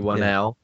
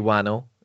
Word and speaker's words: Whannell: which is a James Wan and Lee Whannell, or Lee Whannell, if we're committing to the Horror Hangout pronunciation.